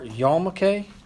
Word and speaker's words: yarmulke: The pronunciation is yar, yarmulke, yarmulke, or they also call it a kippah, K-I-P-A, and there's also, they spell it a couple different ways.